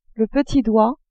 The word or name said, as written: petit doigt